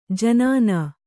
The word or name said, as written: ಜನಾನಾ